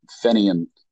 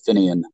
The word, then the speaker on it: Fenian: But was also a mucky muck in the Fenian or Finian, depending on how you pronounce it, movement, Irish Republican movement here in the United States.